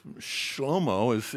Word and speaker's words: Shlomo: He cleans up, and you see, Jesus, Solomon, Shlomo is how you pronounce his name in Hebrew, and it really means peaceable.